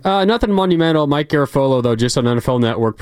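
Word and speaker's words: Garafolo: Well, I am, but it doesn't rhyme with Garoppolo. It's Garofolo.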